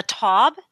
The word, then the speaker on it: Taub: I guess I say a Taub, but I think originally it might have been Taub when it was German, but then it had an E at the end of it.